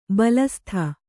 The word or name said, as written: ಬಲಸುತ್ತು